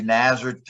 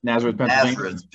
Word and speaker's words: Nazareth: Another one would be Nazareth, I can't even say it right. – Nazareth, Pennsylvania.